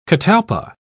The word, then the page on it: catalpa: British English